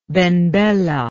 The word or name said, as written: ben bella